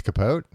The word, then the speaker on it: Capote: Capote. It's Capote? – I've been mispronouncing it this whole time.